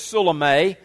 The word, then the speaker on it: Salome: It's thought that possibly her name was Salome. There's a good reason to think that, and some would pronounce it Salome. Either of these pronunciations could possibly be correct, and maybe you have another one you want to use, and that's fine too.